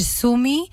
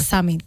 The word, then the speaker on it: Summit: Aquí tú sabes que en Huelva nos permitimos las licencias que queremos y se puede hacer Sumi y lo que tú quieras. – Sami. – Pero hay que mantener la pronunciation y es Summit.